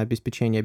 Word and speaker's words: обеспечение: Это, знаете, что в первую очередь я сам эту ошибку больше не повторял, хоть на некоторых сайтах я прочитал, потому что так часто говорят именно обеспечение, обеспечение, вроде как теперь позволяется делать ударение и на третий, и на четвертый слог. Но так как это все-таки происходит от слова «обеспечить», а не от слова «печенье», буду стараться говорить программное обеспечение.